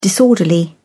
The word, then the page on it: disorderly: British English